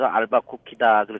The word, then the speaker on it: 앨버커키: 예, 좀 발음하기가 좀 힘든데 제가 소개할 때는 늘 알밤도 주고 쿠키도 준다. 그래서 알바 쿠키다 그렇게 제가 소개를 하고 있습니다. 네. – 들으신 것처럼 뉴멕시코주 인구의 절반 이상이 앨버커키에 집중돼 있을 만큼 앨버커키는 뉴멕시코에서는 가장 큰 도시인데요.